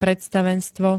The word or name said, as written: predstavenstvo